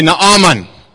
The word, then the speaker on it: Naaman: Because in Afrikaans, you say Naaman. And I didn't really know what that is in English. But I thought it was Naaman, and it is.